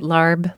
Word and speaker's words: LARB: do you pronounce it LARB, like the Thai food? – I think of it as LARB, like the Thai food.